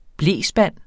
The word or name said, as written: blespand